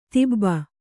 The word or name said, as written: ಧೋಬಿ